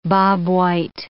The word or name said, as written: bobwhite